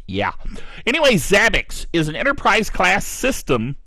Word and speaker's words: Zabbix: Zabbix. I'm pretty sure that's how it's pronounced. Z-A-B-B-I-X.